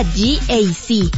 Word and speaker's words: GAC: Se escribe GAC, pero se pronuncia GAC. Repítelo, GAC, que el de al lado te escuche.